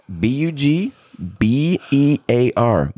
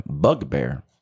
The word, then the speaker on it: bugbear: B U G B E A R. Bugbear. The emphasis is on the on the bug. Bugbear.